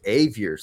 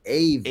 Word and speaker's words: Avior: And technically my name is Avior, son. Avior. – Avior. – That's a hard A, like rhymes with behavior.